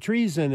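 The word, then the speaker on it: Treeson: It's Treason, and that's spelled treason, it sounds like, but it's T-R-E-E-S-O-N.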